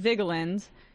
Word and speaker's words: Vigeland: Is the E silent or not? Vigeland or Vigeland? It is Vigeland, even though that's not even how I should be pronouncing it. I think in Norwegian it's Vigeland, but I'm not an asshole, so I don't pronounce it that way. I just, you know, sometimes shorten it like Vigeland, but it's Vigeland.